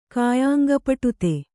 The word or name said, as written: ಕಾಯಾಂಗಪಟುತೆ